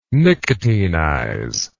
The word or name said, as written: nicotinize